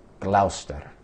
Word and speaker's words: Gloucester: He was in, I believe you pronounce this place, Gloucester. Gloucester. It looks like Gloucester to me, but Gloucester, somewhere in England.